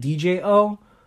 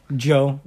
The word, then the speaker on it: Djo: I'm just saying Joe. I was saying DJO, But then I'm like, that's not right. – No. – Joe.